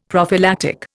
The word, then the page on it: prophylactic: Indian